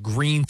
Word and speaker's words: Gruene: When my wife's from New Braunfels, Texas, and there's a really cool little town called Green, Texas. It looks like it's spelled Gruene, G-R-U-E-N-E.